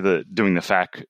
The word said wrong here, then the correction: FACS: training, Ekman's FAQ training, if I'm saying it right. – Or FACS. – FACS. Yep. Yeah. With the S on that.